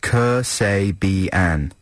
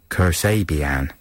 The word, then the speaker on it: Kasabian: Yeah, this is Kasabian, and it says helpfully here on the playlist... Pronounced Kersabian. Kersabian. And it's the fourth single from the Leicester Quartet, who have taken their name from Linda Kasabian.